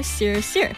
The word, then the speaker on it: Siir: That's one of the FIFA World Cup 2026 songs, and it's spelled S-I-I-R, Sir.